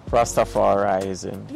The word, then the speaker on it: Rastafarianism: Rastafarianism.